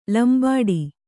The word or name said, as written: ಲಂಬಾಡಿ